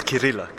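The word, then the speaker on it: Chirila: There are a few more games, but we have a winner, and it is Grandmaster Christian Shirilla. Did I pronounce that correct? – No, it's Kirilla, Christian Kirilla.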